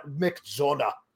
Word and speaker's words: McDonagh: Because you've got to say it like McDonagh. Because there's there's the, the, the GH there's there's a lot of nice roughage in in the in the breathiness of the Irish uh, of the Irish sound.